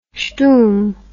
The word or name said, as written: shtum